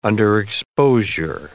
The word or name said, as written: underexposure